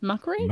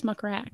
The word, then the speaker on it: muckrake: Is it pronounced muckrake? I thought it was muckrack.